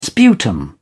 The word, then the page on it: sputum: British English